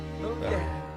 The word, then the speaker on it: Bowie: Bowie.